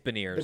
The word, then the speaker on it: Beniers: Am I mangling his name too? I'm pronouncing it like it's French Canadian. – It's Beniers.